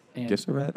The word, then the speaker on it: Gesserit: Gesserit? I don't know how to say it. I think it was a soft G.